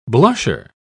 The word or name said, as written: blusher